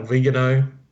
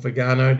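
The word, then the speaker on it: Viganò: But I think the issue, given its prominence at the moment, you've mentioned a few things, James, Cardinal Vigano, or Vigano, depending on your pronunciation,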